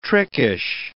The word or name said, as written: trickish